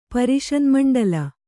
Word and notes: ಪರಿಷನ್ಮಂಡಲ: Kannada

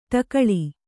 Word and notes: ಟಿಕಳೆ: ṭikaḷe

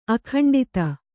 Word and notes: ಅಖಂಡತೆ: Kannada

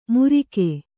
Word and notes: ಮುರಿಗೆ: Kannada

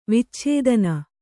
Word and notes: ವಿಚ್ಛೇದನ: vicchēdana

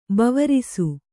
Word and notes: ಬವರಿಸು: bavarisu

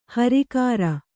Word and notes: ಹುರಿಕರಿ: Kannada

